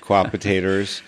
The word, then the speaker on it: Co-opetators: Co-opetators. – Co-opetators. – Rhymes with hot potatoes.